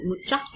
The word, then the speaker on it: Mucha: I would highly suggest, for anime again, looking at Art Nouveau artists, especially Alphonse Marie Mucha. Some people pronounce it Mucha.